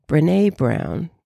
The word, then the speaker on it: Brené Brown: Oh yeah, Brené Brown.